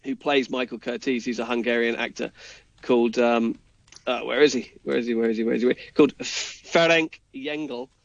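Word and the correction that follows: Lengyel: Okay. – Sorry, Lengel. I hope I'm close to pronouncing that correctly.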